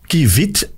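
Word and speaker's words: kievit: De Kivit, of de Kiv, Kivit, uh, eh, amai, Vanellus vanellus is een weidevogel uit de familie van de... Ah, wij blijven... Oh, de naam wordt meestal uitgesproken als kivit.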